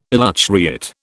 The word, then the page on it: elutriate: British English